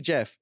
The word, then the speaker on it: Geoff: And I'm very happy that I managed to pronounce his name properly, because when I first see G-E-O-F-F, I always call it Geoff. But I've learned it's actually Jeff, okay? – Yeah.